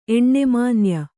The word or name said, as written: ಎಣ್ಣೆಮಾನ್ಯ